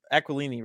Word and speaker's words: Aquilini: I know Francisco Aquilini, right? That's how it's pronounced.